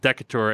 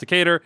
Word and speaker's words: Decatur: And I butchered Decatur on the first time, and I said Decatur, and then the next person said, hey, it's pronounced Decatur.